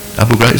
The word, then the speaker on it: Abu Ghraib: Just think with me, what all those terrible things those American soldiers did in that Abu Ghraib prison. Abu Ghraib, is that how you pronounce it? Yep, in Iraq.